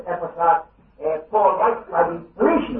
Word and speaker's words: Epaphras: Some call him Epaphras, and some call him Epaphras. Well, they might both be wrong, I don't know, but I generally say Epaphras, but if you think my pronunciation is wrong, well, we're not talking about that.